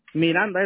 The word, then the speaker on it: Miranda: ¿Cómo se escribe Miranda? B de... – Miranda es con M.